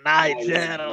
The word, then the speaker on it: Niger: Niger, lo pronuncio all'inglese, un rock un po' insolito, ma sempre rock con la sua chitarra del deserto, Bombino a Medellin.